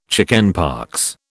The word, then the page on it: chickenpox: British English